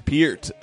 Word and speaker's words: Peart: Yeah, it's Peart.